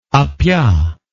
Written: appia

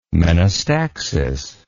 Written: menostaxis

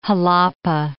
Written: Jalapa

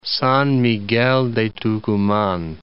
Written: San Miguel de Tucuman

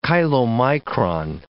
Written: chylomicron

